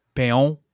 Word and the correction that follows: Paon: It's a Minestrone soup. This is Paon. It's French for, I looked it up afterward, French for peacock.